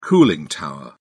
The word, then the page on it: cooling tower: British English